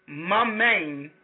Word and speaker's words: Mammon: Now, this and it's pronounced Mammon, it's M-A-M-M-O-N, you can look it up yourself, and it derives from late Latin and Greece and Syrian.